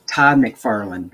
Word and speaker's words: McFarlane: McFarlane. Todd. Thank you. Todd McFarlane.